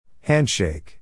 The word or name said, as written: handshake